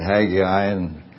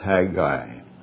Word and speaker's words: Haggai: Sometimes I've said Haggai, but I think proper pronunciation is Haggai.